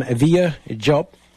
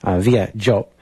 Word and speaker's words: Vieux Diop: Before that, we heard music from Villa Jope. And from his album, which is also his name, Via Jope, and it's written, it's spelled V-I-E-U-X-D-I-O-P.